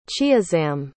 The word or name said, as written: chiazam